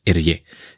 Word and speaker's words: Hergé: que por si os lo preguntáis es la pronunciación en francés de sus iniciales con el orden eh, invertido. De Georges Remy, o sea, GR, si lo, eh, si lo invertimos es RG, y en francés Hergé.